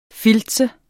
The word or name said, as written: filtse